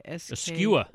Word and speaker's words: skua: Skua. – Skua.